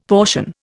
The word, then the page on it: portion: Indian